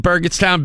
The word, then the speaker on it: Burgettstown: Isn't Burgettstown big enough for him? – Apparently Mick doesn't. – Is it a hard G or a soft G? – It's a hard G, yeah.